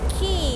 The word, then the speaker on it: Quay: คีสะกดหน้าตาแปลกๆนิดนึงนะ QUAY แต่คำนี้อ่านว่าคีเหมือนคีกุญแจละค่ะ